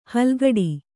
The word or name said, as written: ಹಲ್ಕಡಿ